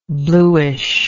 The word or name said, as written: blueish